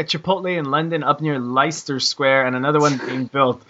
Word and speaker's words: Leicester Square: Am I pronouncing that right, Mike? Leicester Square? – Leicester Square. – Sorry? – Leicester Square, isn't it? – Leicester Square. That's how you pronounce it.